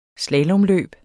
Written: slalomløb

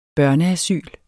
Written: børneasyl

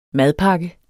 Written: madpakke

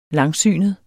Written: langsynet

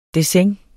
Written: dessin

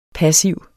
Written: passiv

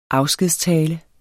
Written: afskedstale

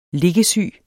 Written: liggesyg

